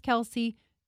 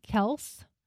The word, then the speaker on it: Kelce: and the dad was saying that we're actually pronouncing their last name wrong. – Really? – Yeah, it's actually Kelce. – Oh, okay. – But when they got into the NFL, everyone was pronouncing it Kelce,